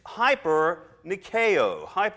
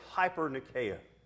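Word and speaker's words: hyper nikeo (ὑπερνικάω): It's hyper nikeo, or hooper nikeo, however you want to do your Greek pronunciation. I'll say hyper nikeo.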